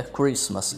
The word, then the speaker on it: Christmas: A escrita de Christmas possui um Tzinho entre o S e o M. Mas só que quando a gente pronuncia, esse T é mudo. Fica Christmas e não Christmas.